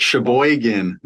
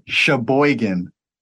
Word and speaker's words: Sheboygan: They had like 8 or 10 or 12, I don't even remember their staff they brought down from Sheboygan, or however you say it. – Sheboygan.